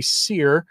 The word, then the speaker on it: Sire: And it's by Henry Sire. Yes, that's how I heard him pronounce his own name in his own in a speech he gave that was put up in podcast form.